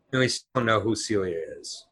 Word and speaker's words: Silja: He wrote in to say the name that we kept saying Silja S I L J A is not pronounced as Silja. It is pronounced as Celia.